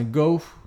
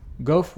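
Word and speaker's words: Gough: Vas-y, dis, dis, Bah, c'est Alfred Gou, je sais pas comment ça se prononce, hein. Goff. Goff, voilà,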